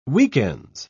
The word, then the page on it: weekends: British English